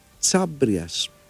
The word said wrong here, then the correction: Χαβρίας: Χάμπριας. Δεν ξέρω πώς ακριβώς διαβάζεται αυτό. Και τι είναι ακριβώς. Είναι. Α, συγγνώμη, Χαβρίας. Αθηναίος στρατηγός του 4ου αιώνα. – Χαβρίας.